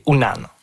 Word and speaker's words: Unan: Puis le vrai mot Innu, c'est Unan, ou parfois prononcé Wanan.